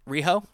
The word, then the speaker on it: Riho: We have Rio. Is that how you say her name? – Riho? – Yep. Riho and Hikaru Shida? – Hikaru Shida.